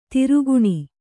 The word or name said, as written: ದೊರೆಗಾಣ್